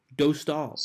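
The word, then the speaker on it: Dostál: I have a Czech friend on my roller hockey team who I asked him for pronunciation advice, and apparently it's Lucas Dostal. – Dostal.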